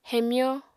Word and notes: hemyó: apatani

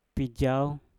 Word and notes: pijauw: Mpur

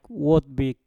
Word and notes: wot bik: mpur

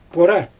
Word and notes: pora: Xyzyl

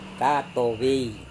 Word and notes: katovei: Serewen

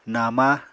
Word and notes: nama: Apatani

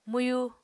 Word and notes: mwyu: Apatani

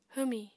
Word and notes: hwmi: apatani

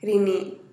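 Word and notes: rinii: Teotitlán del Valle Zapotec

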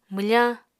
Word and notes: mwfañ: Apatani

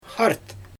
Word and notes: xart: Xyzyl

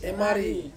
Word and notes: mari: Ansus

